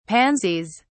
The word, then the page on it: pansies: British English